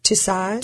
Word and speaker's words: Tussauds: Tussauds.